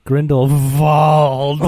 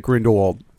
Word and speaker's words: Grindelwald: I heard all the other people say Grindelwald, and then I heard Dumbledore be like, Grindelwald. – Only the muggles pronounce it Grindelwald. You, if you're a true magic person, you say it with a V.